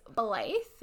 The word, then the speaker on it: Blythe: Search my name, Blythe Brokaw. – Can you spell that for people? Because obviously it's not a typical. – It's Blythe, B-L-Y-T-H-E, mm-hmm. and Brokaw, B-R-O-K-A-W.